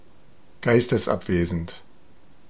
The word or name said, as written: distrait